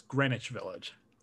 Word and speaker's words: Greenwich: It's Greenwich Village. – Excuse me. I'm from Nebraska. – The W is silent, I've learned.